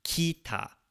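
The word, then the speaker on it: Kita (北): Kita. Kita ga. Kita ga. So we're just kind of, again, swallowing that I sound.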